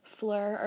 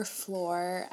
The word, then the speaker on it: Fleur: So, starting off with her name, her name is Fleur or Floor. I think I've heard people pronounce it both ways. I always just say Fleur.